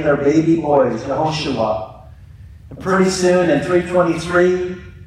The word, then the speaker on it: Yehoshua: In Hebrew, it's pronounced Yehoshua. Yehoshua.